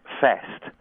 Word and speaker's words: feast: Yeah, so hast rhymes with tast, and there's also another one, which is the word feast that comes up a lot in Shakespeare. Mm-hmm. Feast becomes fest, and so it rhymes with guest. So you'd be a guest at a fest.